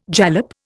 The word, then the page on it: jalap: Indian